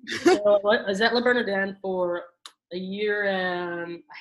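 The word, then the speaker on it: Le Bernardin: I was going to call it La Bernardini, but I have to say it correctly the first time, La Bernardin.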